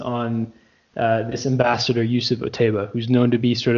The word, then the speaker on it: Otaiba: named um, Otaibi. Otaibi, what? How do you say his name? – Yusuf Otaiba.